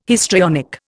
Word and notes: histrionic: Indian

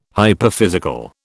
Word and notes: hyperphysical: British English

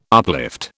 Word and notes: uplift: British English

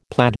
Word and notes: plaid: American English

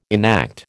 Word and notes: enact: American English

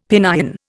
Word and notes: Pinyin: Indian

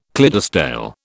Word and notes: Clydesdale: British English

